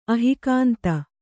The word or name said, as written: ಅಹಿಕಾಂತ